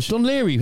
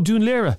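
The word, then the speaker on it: Dún Laoghaire: Dunleary. How do you pronounce it? – Oh, Dunleary. – You don't, actually. – No, I do. – That's the point. You don't. – Dunleary. – It should be Dunleary.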